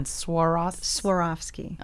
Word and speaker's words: Swarovski: Swarovski. – Okay. It's not easy to say. – You went skiing, you broke your leg, you swore off skiing. – Swarovski.